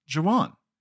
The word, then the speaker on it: Juwan: It's actually pronounced Juwan, as in Juwan Howard. Remember Juwan Howard?